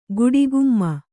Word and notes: ಗಡಿಗೆಮ್ಮ: gaḍigemma

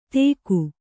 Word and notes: ತೇಕು: tēku

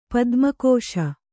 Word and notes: ಪದುಮಾಕ್ಷ: Kannada

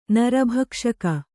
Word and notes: ನರಭಕ್ಷಕಿ: kannada; nara bhakṣaki

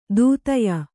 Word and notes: ದೂತ್ಯ: Kannada

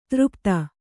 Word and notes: ಧಾರಾಪಾತ: kannada

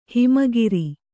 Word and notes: ಹೆಮ್ಮೆಗಾರ: Kannada

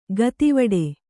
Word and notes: ಗತಿವಡೆ: Kannada

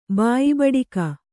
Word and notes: ಬಾಯಿಬೂಟಕ: kannada

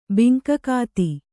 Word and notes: ಬಿಂಕಗಾತಿ: binkagāti

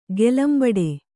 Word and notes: ಕಲಂಬಡೆ: kalambade

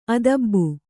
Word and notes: ಆದಾಬು: ādābu

